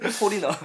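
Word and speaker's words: Foreigner: 근데 확실히 이게 이게 포리너라서 F 나... 발음 주의해 주시고요. 포리너.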